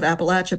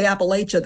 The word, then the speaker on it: Appalachia: And here I will pause a little bit on the pronunciation of Appalachia, because in the southern mountains, this is what we say. If you say Appalachia, they'll throw an Appalachia. In the northern range of the Appalachians, of course, they do pronounce the long A. So I will resort to Appalachia.